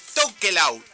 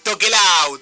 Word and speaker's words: Tokelau: amigos, estamos acá ya con los chicos de Tokelau. ¿Cómo se pronuncia? A ver, decime porque Tokelau.